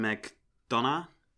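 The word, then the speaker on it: McDonagh: Und äh, der heißt Martin McDonough. Mhm. Wird er, glaube ich, ausgesprochen. Das wusste ich letztes Mal nicht. Dieses Mal habe ich es extra nachgeguckt.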